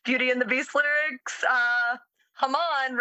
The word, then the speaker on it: Haman: For the English pronunciation, Haman is the English pronunciation. But when you say it in Hebrew, it is Haman.